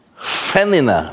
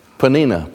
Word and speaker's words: Peninnah: And the name of the second, it's pronounced Fanina, Fanina, Fanina but we're going to just call it like it looks up there Peninnah, Peninnah, Peninnah.